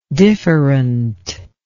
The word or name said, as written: differentness